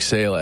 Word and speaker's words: Saleh: Is that how you say it, Saleh? – That's him. – Robert Saleh.